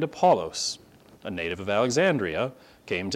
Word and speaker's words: Apollos: I hear people pronounce it different sorts of ways. I double-checked the actual Greek, and if you want to call him what his mama would have called him, Apollos would be his name.